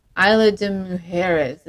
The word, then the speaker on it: Isla de Mujeres: But then we also went to Isla de Mujeres. Is that how you say it? – It sounded great.